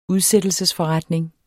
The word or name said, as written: udsættelsesforretning